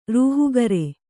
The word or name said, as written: ರೂಹುಗರೆ